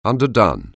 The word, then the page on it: underdone: anglais